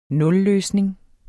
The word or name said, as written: nulløsning